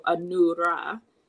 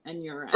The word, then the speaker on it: Anura: So I have an Asian name, and the first three letters of my name, ANU, is pronounced Anu in those cultures. And so that would change how you would say my name depending on where you come from. So Anura rather than Anura.